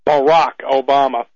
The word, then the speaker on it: Barack Obama: But the very fact that Barack Obama, and I'm trying to you stick to that pronunciation, I guess that's how he pronounces his name, Barack Obama.